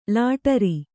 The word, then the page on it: ಲಾಟರಿ: kannada